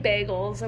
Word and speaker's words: bagel: So the rest of us are eating bagels. And what are you eating? – Bagels. – Bagel. – Bagels? Bagels? – Bagels? Bagels. – Stop saying bagel. It's not bagel. It's spelled B-A, not B-E.